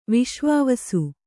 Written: ವಿಶ್ವಾವಸು

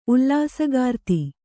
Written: ಉಲ್ಲಾಸಗಾರ್ತಿ

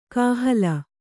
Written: ಕಾಹಲೆ

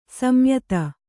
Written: ಸಂಯುತ

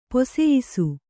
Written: ಪೊಸಯಿಸು

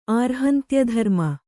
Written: ಆರ್ಹಂತ್ಯಧರ್ಮ

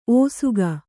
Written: ಒಸಗು

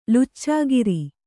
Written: ಲುಚ್ಚಾಗಿರಿ

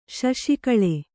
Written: ಶಶಿಕಳೆ

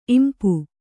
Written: ಇಂಪಿ

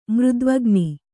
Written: ಮೃದ್ವಗ್ನಿ

